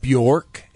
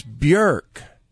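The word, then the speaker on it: Björk: It's not Bjork, it's Bjork.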